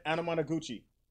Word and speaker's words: Anamanaguchi: Anamanaguchi.